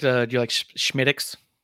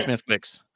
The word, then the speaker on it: Smithwick's: It's spelled Smithwick's, and everyone thinks it's Smithwick's. And it's a Guinness product, but it's really good. But when you go to a bar and you're trying to impress the bartender, you call it Schmidix.